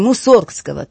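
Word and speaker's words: Мусоргский: Концертный вечер посвящен творчеству великого русского композитора Модеста Петровича Мусоргского, или, как правильно говорили раньше при его жизни, Мусоргского. Так назывался его старинный дворянский род — Мусоргские.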